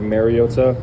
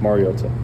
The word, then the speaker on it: Mariota: I think it's been said over the years like Mariota, which is incorrect, and it's pronounced Mariota.